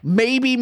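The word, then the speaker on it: Maybee: Maybe Michigan. So it's maybe in Michigan, but it's spelled with two E's.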